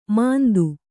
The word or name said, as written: ಮಾಂದು